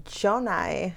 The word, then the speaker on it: Gjonaj: So now it's, what is it, Janisa Jonai? – Jonai. – Jonai. – You got to say it like that, Jonai. Like with a swag.